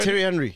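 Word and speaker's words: Thierry Henry: I mean, I remember Minnie was married and then Terry Henry. How do you say it? – Terry Henry. Yeah, yeah.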